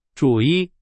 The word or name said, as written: 主意